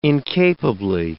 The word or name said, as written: incapably